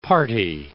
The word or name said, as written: party